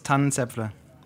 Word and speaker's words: Tannenzäpfle: You mean the Tannenzäpfle? – Yeah, that one. – Rothaus Pills, Tannenzäpfle.